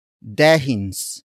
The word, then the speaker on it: dehins: The next slang word is dehins. Dehins. Dehins. Dehins is spelled as D-E-H-I-N-S.